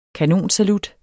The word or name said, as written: kanonsalut